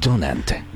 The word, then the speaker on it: Dunant: Ok, Donant.